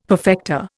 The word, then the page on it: perfecta: Indian